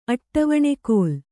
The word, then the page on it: ಅಟ್ಟವಣೆಕೋಲ್: Kannada; aṭṭavaṇe kōl